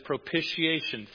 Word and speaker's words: propitiation: Well, somebody looked it up and said it's propitiation. Think initiation, right? Initiation. It sounds just like that.